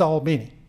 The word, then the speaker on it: Albany: And in Georgia, it is Albany. As a waitress there told a friend of mine one time, when they asked her how you pronounce the name of the town, she said, "Honey, it's Albany, just like Albany, New York."